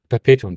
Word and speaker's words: Perpetuum: Perpetuum ist das? – Ja, Perpetuum, danke. – Perpetuum hat 4 Silben. – Nicht, wenn du es schnell aussprichst.